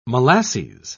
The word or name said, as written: molasses